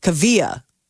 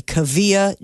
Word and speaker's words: Kaavia: And it's Kavia. So that is how you pronounce it. It's spelled K-A-A-V-I-A, Kavia James.